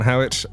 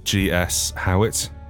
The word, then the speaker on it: Howitt: And my name's Grant Howitt. I am G.S. Howitt, or G. Showitt, if you want to pronounce it incorrectly, pretty much everywhere. That's with Howitt with 2 T's.